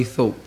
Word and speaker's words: Thorpe: Johnny Segru, did I pronounce that right? – My name is Johnny Thorpe. Yeah, yeah. Johnny, T-H-O-R-P-E.